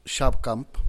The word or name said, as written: xabkamp